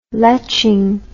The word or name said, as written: leching